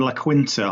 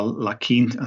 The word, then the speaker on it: La Quinta: You've lost kind of La Quinta or La Quinta.